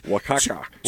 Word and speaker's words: Oaxaca: 2 ounces of mild cheddar cheese, 2 ounces of, I don't even know how to say this, Osaka cheese. – Oaxaca.